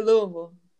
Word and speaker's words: Louisville: Louisville. Have I got that wrong? Oh, I feel like I butchered that completely. – how, how do you say it? Say Louisville.